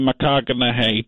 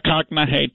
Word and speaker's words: McConaughey: It'll allow Matthew McConaughey to address the team. – Check your pronunciation there for a guy that you claim is your friend. – It looks like McConaughey to me.